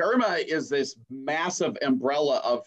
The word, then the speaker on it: ERMA: It's called Irma. Mm-hmm. E-R-M-A is how that's spelled.